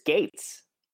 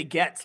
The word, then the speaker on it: Gaetz: How do you pronounce the other guy's name? – I think it's Gates. – Oh, is it Gates? I always say Getz, like Bernie, which I think is kind of funny.